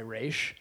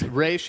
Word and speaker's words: Ra's: Sorry, Ra's. – Yes, Ra's. In the animated series, we call him Ra's. – We call him Ra's al Ghul. – That's how he's pronounced.